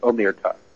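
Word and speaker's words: omertà: One of the things that you mentioned, Dr. Stan, is the code of, I believe it's pronounced Omerta,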